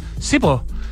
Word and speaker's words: SEEPO: No sé si les ha tocado ver las ópticas Sipo, que se escribe como S-E-E-P-O, como Sipo, que son realmente atractivas, ultramodernas, entretenidísimas, con un look así como medio industrial.